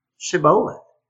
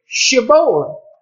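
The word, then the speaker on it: Shibboleth: The Gileadites, according to grammarians and historians, were accustomed to say Sheboleth. But the Ephraimites said Sheboleth, pronouncing the Hebrew letter shin as the Hebrew samed, S, instead of SH.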